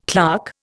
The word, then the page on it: clerk: Indian